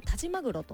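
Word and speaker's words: 但馬玄: そう田島玄と書いて田島マグロと読むんですけれども、田島マグロというマグロじゃないんですよ、田島マグロという牛ですね。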